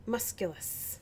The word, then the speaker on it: musculus: And what is it? – Muscule? – Musculus. – Musculus.